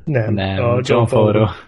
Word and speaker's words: Favreau: Jó, úgyhogy ez volt a büntető, és akkor a következő filmünk az a Séf 2014-ből, ami igazából egy ilyen John Favreau, úgy kell kiejteni az illető nevét, ugye? – Hát mondd azt, Favreau. és Favreau, Favreau és Favreau.